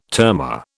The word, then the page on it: termor: British English